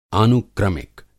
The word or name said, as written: आनुक्रमिक